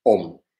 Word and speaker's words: Om: Dice, el sí mismo absoluto Está representado por la sílaba sagrada AUM, AUM, OM, llamada Pranava. Eh, ahora explico por qué se dice OM, si, queréis.